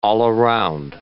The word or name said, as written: all-around